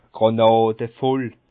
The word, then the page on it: Grànade voll: Bas Rhin; Schiltigheim